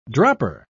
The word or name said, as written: dropper